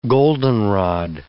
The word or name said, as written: goldenrod